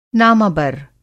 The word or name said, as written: नामाबर